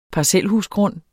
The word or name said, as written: parcelhusgrund